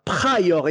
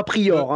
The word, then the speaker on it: Pryor: Et encore, j'ai fait l'effort de dire Pryor et non pas Prior, hein, quand même.